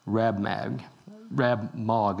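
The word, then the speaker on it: Rab-Mag: One through three, under Darius, Daniel is made chief over all the wise men and has given the title Rab Mag is actually the correct pronunciation.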